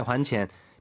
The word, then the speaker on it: 还钱: 이때 이제 그 환이 발음 유의하시기 바랍니다. 还钱, 돈을 갚다죠. 欠债, 还钱.